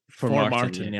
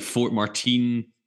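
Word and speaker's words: Formartine: is that how you pronounce it? Formartin? – Formartin, yes. – So I do a lot of radio. That's the team I've bastardized the most. Like Fort Martin.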